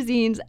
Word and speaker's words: zines: I said it right this time. They took their zines, and it was always characterized with really bright colors.